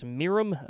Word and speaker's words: M!R!M: And a new artist that I've actually never played on the show before after that called, I'm going to say it's pronounced Miram. It's actually spelled M exclamation point R exclamation point M. That is how it's spelled.